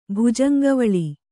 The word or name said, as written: ಭುಜಂಗವಳಿ